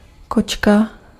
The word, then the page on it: cat: /ˈkæt/